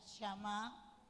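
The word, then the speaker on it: shamá (שמע): En hebreo la palabra obediencia se pronuncia shamá, que transmite la idea de escuchar atentamente, escuchar atentamente para obedecer.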